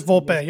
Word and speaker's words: Volpe: Anthony Volpe, is that how you pronounce it? – Yes, I don't know. It is Volpe, yep.